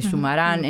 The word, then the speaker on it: Zumarán: que se escribe con Z, pero suena igual que Sumar, ¿no? De Zumarán.